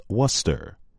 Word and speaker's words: Worcester: Like the city of the same name in England, the correct pronunciation is Worcester. It is not Worcester or Worcester.